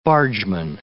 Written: bargeman

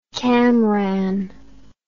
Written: camranh

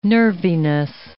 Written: nerviness

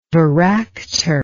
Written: varactor

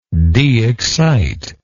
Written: deexcite

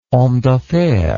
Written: homme d'affaires

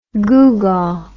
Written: googaw

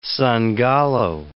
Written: Sangallo

